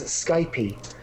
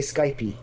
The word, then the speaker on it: Skype: One of which is, of course, my pronunciation of Skype as Skypey. Which I must admit I did because I work in an office full of French people, being in France as I am, and they all say Skypey, so I just picked it up, assuming that was the right way to pronounce it, and I have been corrected since by the Skype website that tells us itself that it's pronounced Skype.